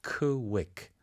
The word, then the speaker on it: quick: 자, 이때 quick이라고는 하지 않고요. quick, 그죠? – 한국에서는 이제 cooey라기보다는 자꾸 키키키 이런 발음이 나오거든요. – 네. – 그래서 천천히 KW 사운드, quick.